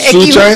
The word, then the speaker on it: Xuxa: Aquí cuando la gente no, quiere esa palabra, Sucha es X al principio y X en el medio. – Por eso. – Pero al principio lo pronuncia con una con S y en el medio con Che. Sucha.